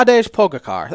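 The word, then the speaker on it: Tadej Pogačar: Tajay Poga Car. That's way off. Yeah, you bungled that one.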